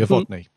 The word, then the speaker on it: Uvodni: I can't remember how to pronounce it. It's something like Uvdani, the Uvdani race or something like that. – Yeah. – The Uvodni. Yeah, that's it.